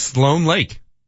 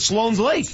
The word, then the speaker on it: Sloan Lake: It is Sloan Lake. – It is? – So I've been saying it wrong all this time. – I grew up in that area. I've always called it Sloan's Lake. – Sloan Lake, also known as Sloan's Lake.